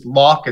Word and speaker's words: Lalk: So my last name is Lalk.